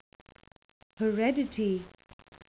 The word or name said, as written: heredity